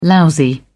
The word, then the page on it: lousy: British English